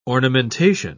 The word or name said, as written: ornamentation